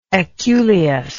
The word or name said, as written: aculeus